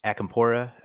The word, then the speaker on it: Acampora: So it's Anthony Acampora. That's A-C-A-M-P-O-R-A.